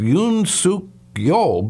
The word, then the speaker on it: Yoon Suk-yeol: I believe it's pronounced Yoon Suk-yeol, but I'm not sure. Bruce, am I pronouncing the guy's name right? – Yeah, that's good enough.